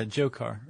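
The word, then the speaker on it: Dzhokhar: And then the one, I guess, is that Zokar? The D is silent, right? – Yeah, Jokar or Jokar.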